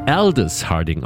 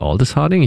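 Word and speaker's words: Aldous Harding: Sie wird Aldis Harding ausgesprochen, nicht Aldis Harding. Ich weiß gar nicht, warum ich immer darauf so ein langes A machen möchte.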